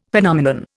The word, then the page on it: Phenomenon: Indian